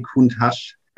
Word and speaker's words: Countach: Da gab es ja noch einen dritten äh, Traumwagen in den 80ern, nämlich den Lamborghini Countach. Kuntach, Kuntach, wie, wie spricht man es aus? Lamborghini Countach.